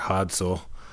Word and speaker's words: Hadsel: You know, whether you're in a city, destination studio, or in the case of your new record, Hadsel. I hope I'm pronouncing that correctly. – That was actually closer to how the Norwegians say it. They say it kind of Hadsel, or like almost like, yeah, I mean, that was pretty good, so.